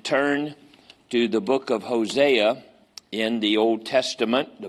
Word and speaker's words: Hosea: Hosea, that's his name. That's how they pronounce it in Israel, anyway, when they speak English to you, Hosea.